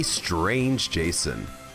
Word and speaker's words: Strange Jason: It rhymes with Tame Mason. Give up? Give up everything? And follow me, for I am the host and curator of this little show, the one and only Strange Jason.